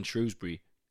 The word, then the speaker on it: Shrewsbury: But there's half of the crowd in Shrewsbury get really angry when you say Shrewsbury because half of them pronounce it Shrewsbury.